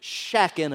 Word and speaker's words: shacking: So write down number two, shacking up. And if you pronounce it right, you don't pronounce the G. You're shacking. Shacking up.